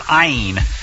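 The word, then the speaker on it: ayin (אין): Matter of fact, there's a Hebrew word, ayin, and it means ain't. The Hebrew word literally rhymes with ain't, and it means ain't.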